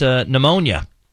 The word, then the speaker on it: pneumonia: How about pneumonia? – Ooh, all right. That starts with a P. – P H. – P is silent.